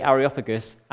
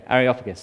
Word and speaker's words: Areopagus: It was only yesterday that Naomi told me that you don't say Areopagus, Areopagus. So thank you, Naomi, for that. There you go, Areopagus.